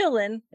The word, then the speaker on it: Qilin: which the way it's spelled you would not think that's how it's pronounced because it starts with a Q, but you call it the Qilin.